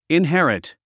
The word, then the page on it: inherit: inhérit; インヘリト